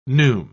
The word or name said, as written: neume